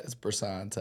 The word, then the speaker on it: Bresson: It's Bresson.